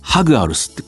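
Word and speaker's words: Jaguares: でまあ、このジャガーズ海外の放送を聞いてるとハグアルスってこう、ちゃんとスペイン語の発音でニュージーランドのコメンテーターは言いますね。ハグアルス。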